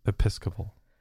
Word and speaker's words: Episcopal: And then that night they went to the twilight service at St. John's Episcopal Church in Boulder. Did I say that right? – Yes. Episcopal.